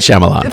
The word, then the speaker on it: Shyamalan: and Shyamalan. How do you pronounce it? – You did just fine. M. Night Shyamalan.